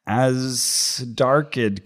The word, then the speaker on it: Azhdarchid: Azdarchid kind, A-Z-H-D-A-R-C-H-I-D.